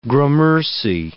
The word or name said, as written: gramercy